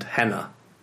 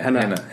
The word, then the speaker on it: Henne: I'm glad you've already said henna. – Yeah. – Because that's what I'm going to pronounce it as now. – Yeah. – Because I've heard it hen. – Hen is incorrect, yeah. – Right, so it is henna.